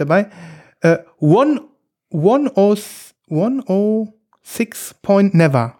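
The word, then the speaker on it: Oneohtrix Point Never: Deswegen sagt man nicht Oneotrix Point Never oder ähnliches oder Uneotrix, sondern man sagt 1.06 Never. 1.0, nee, 1.0.6 Never.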